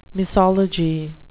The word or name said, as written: misology